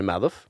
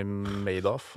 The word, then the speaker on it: Madoff: Bernie Madoff? Bernie Madoff. Madoff? Madoff. – Mener du Madoff? – Han heter Madoff. Er det Madoff? Ja, det er sånn de uttaler det, i hvert fall. Er det ikke Bernie Madoff?